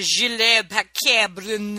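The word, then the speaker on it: Gilles Paquet-Brenner: And it's this French director, Gilles Paquet-Brun. – Yep. – That's how you say it in French.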